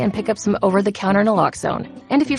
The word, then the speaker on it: naloxone: For the record, it's Naloxone. Na, like, is Naloxone addictive? Nuh-uh. Locks like Naloxone keeps humans safe like locks keep bikes safe, and own like Naloxone can save lives in our very own communities.